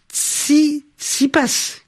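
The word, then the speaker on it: Tsitsipas: Dans la suite de l'activité, le, tennis avec le rêve de Stéphanos Tsi six passes qui continue à l'Open d'Australie. – c'est ainsi qu'on prononce euh, le nom de ce joueur grec de 20 ans qui s'est qualifié pour les demi-finales de l'Open d'Australie.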